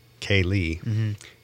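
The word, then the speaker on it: céilí: Yeah, and Kaylee is spelled C-E-I-L-I. – With some accents over those letters.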